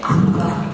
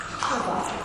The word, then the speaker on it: Kuba: It's a bit of H, eh? – Kuba. – And the B sucked in. – Kuba.